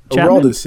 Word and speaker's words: Aroldis: How do you say this guy's name? Aroldis? – Aroldis, yeah.